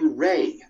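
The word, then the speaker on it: Rey: Is it pronounced Ryan or Rain? I can't pronounce it. Well, that's a difficult question. I don't know what the weather thing. – Is it pronounced Ryan or Rain? It's actually Ray.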